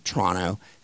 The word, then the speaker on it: Toronto: And then I was in Canada, and I swear, I went to a place in Toronto, in a basement, Toronto, and that's how they say it, T-R-O-N-N-O, that's how you pronounce it.